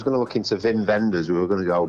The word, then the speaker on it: Wim Wenders: While you're there, Wim Wenders, right, his name begins with a W, but that is official, that's how you say it.